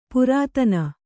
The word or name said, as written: ಪುರಾತನ